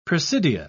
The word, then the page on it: presidia: British English